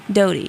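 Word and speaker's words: Doughty: It looks like Doherty, but it's not Doherty. Like Doty? – Doty. Yeah, it's Caitlin Doty.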